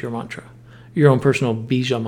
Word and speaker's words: bija: is Om Bija Namaha. So Om Bija Namaha. That middle syllable is B-I-J-A, if you need a visual.